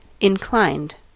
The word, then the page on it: inclined: American English